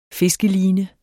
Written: fiskeline

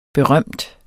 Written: berømt

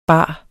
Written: bare

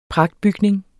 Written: pragtbygning

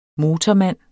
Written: motormand

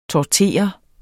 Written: tortere

